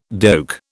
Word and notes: doek: British English

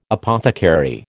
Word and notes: apothecary: American English

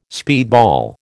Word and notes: speedball: American English

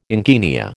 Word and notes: encaenia: American English

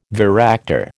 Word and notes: varactor: American English

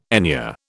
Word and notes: enure: British English

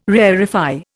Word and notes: rarefy: Indian

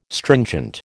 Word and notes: stringent: American English

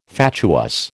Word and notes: fatuous: American English

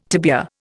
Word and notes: tibia: Indian

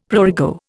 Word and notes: prurigo: Indian

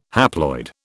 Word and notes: haploid: British English

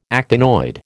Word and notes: actinoid: American English

